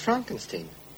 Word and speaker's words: Frankenstein: Frankenstein. – You're putting me on. – No, it's pronounced Frankenstein.